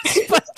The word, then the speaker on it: aespa: But like if you combine it, it's aespa, but then like, separately it's I.